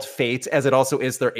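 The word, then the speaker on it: F8: I, I the consensus is that Five Finger Death Punch is, is pronounce fates, not f 8 Because Gretchen and I were in the car where we heard Grant Random on XM call it f 8 And that just doesn't make sense.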